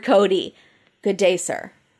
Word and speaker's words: Coty: And also, the guy, it's Cody. And I know it's confusing because it's spelled C-O-T-Y, but it's Cody, sir.